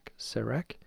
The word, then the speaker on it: serac: I believe it's pronounced Serac. I had to Google it.